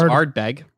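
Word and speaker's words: Ardbeg: It's B-E-G. I don't think there's an R. – CJ's right. It's Aardberg.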